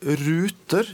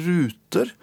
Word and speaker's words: Ruter: Eh, jeg kommer med et selskap i dag som heter Ruter, reisen hit. Og det trodde betydde som bussruter og ferjeruter og båtruter, men mange uttaler det Ruter. Som om det har med kortspill eller dataruter og sånn.